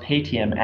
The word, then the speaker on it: Paytm: Like ATM, it's pronounced Paytm.